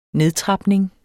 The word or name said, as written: nedtrapning